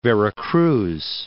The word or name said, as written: Veracruz